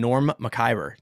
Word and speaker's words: Norm McIver: And he wants to know, what was it like playing with the legend Norm McIver? Did I say that right? – Yes. Yep. Okay.